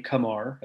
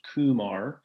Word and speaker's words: Kumar: And that was great, because Kumar Rocker which we learned, and this is important, we learned his preferred pronunciation of his first name, Kumar, as opposed to Kumar.